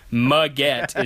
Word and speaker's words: Maggette: Of course Corey Maggette is going to respond to this. Why would he not? It's perfect. His name is Maggette, which rhymes with baguette."